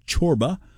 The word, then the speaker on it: Csorba: C-S-O-R-B-A is how you spell his last name, pronounced Chorba, Thomas Chorba.